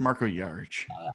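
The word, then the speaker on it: Jarić: Jaric, you know, just wanted to help you out there. It's Marco Jaric.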